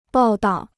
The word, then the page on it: 报到: bào dào